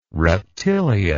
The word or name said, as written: reptilia